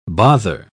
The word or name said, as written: bother